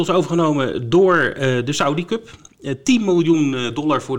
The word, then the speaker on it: Saudi Cup: Ja, Saudi, Saudi Cup. Hè. Wordt op zijn Engels uitgesproken.